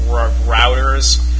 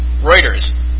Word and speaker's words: Reuters: routers. I said that right, right? – Who? – Routers. Rotors? – Oh. – I'm sorry, I don't even know how to say it. Anyway. – Reuters.